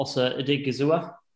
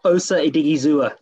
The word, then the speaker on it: Osa Odighizuwa: We're going to stick on defence for a little bit, and you're going to go to UCLA for Osa Odighizuwa. – That's right. You got the pronunciation there spot on. So I don't know if I did, to be honest. – I'll try Osa Odighizuwa.